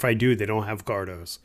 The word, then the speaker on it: Cardo: But the Cardos, is that how you say it? Cardo? – Yeah.